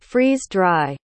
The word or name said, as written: freeze-dry